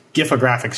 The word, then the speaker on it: Gifographic: And over the holidays, like you just mentioned, you really took it to the next level with the 25-part Gifographic series. And we talked a little bit before the call about the best way to pronounce that. We both agree. Hard G, Gifographic.